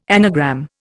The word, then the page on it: anagram: Indian